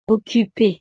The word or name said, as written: occupe